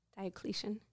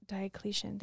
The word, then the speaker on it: Diocletian: So because the church was still new at the time, under the reign of Diocletian. – Diocletian, thank you.